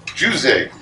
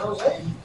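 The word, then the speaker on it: José: So King Jose, is how you pronounce it? – Not Jose? – No, not in Portugal, not in Portuguese.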